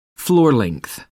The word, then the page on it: floor length: American English